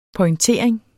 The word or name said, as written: pointering